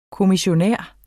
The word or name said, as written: kommissionær